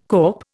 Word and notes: kop: Indian

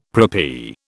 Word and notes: preppy: British English

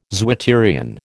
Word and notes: zwitterion: American English

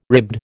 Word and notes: ribbed: American English

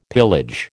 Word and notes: pillage: American English

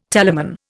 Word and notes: telamon: Indian

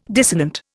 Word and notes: dissonant: Indian

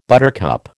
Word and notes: buttercup: American English

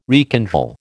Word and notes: rekindle: American English

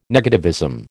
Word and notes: negativism: American English

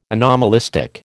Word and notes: anomalistic: American English

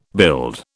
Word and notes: build: British English

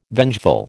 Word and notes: vengeful: American English

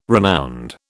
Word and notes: renowned: British English

